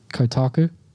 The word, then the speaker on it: Kotaku: At the very last sentence, this is on Kotaku. – Yeah. – Is that how you say it? – Kotaku.